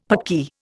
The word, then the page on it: Paki: Indian